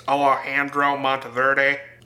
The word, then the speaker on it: Alejandro Monteverde: So it's directed by Alejandro Monteverde. I'm not going to say it like Americans. Alejandro Monteverde.